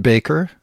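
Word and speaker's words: Bakker: I've got some choice recordings of, I think he pronounced it Baker, and Tammy Faye, his wife, for a little while.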